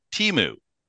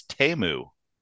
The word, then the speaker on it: Teemu: Before we were recording, I was calling him Teemu. – Yes. – As I always have for my entire life. – Yes. – I just looked at the pronunciation guide. It's Teemu. – Oh, it's Teemu.